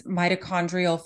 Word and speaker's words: mitochondrial: I'm probably going to butcher this, mitochondrial function. – Yes, you did perfectly.